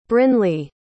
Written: brynleigh

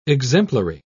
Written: exemplary

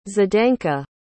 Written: zdenka

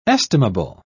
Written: estimable

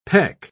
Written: peck

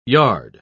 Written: yard

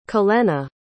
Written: cholena

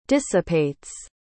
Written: dissipates

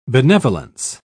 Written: benevolence

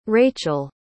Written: rachael

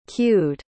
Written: queued

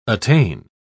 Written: attain

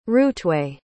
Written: routeway

